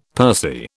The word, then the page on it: pursy: British English